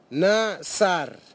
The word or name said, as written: nasar